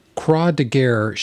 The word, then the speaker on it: Croix de guerre: A little tip Croix de guerre should be pronounced like you ask for a croissant, or as I call it, a croissant.